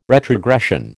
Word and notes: retrogression: American English

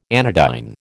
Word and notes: anodyne: American English

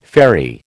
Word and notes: fairy: American English

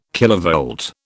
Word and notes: kilovolt: British English

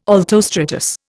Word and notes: altostratus: Indian

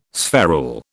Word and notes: spherule: British English

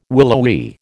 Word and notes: willowy: American English

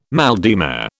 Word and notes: mal de mer: British English

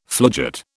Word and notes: floodgate: British English

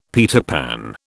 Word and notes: Peter Pan: British English

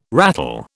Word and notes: ratel: British English

